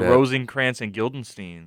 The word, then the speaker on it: Rosencrantz and Guildenstern: They're like Rosencrantz and Guildenstern. Is that right? Did I say that right? – Yeah.